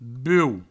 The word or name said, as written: buou